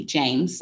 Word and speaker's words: James: So my pen name for those books is Lee James. So Lee is spelled L-E-I-G-H and then James, J-A-M-E-S.